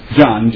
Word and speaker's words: John: It's John, J-O-H-N, not Jim.